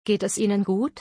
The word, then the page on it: Geht es Ihnen gut: German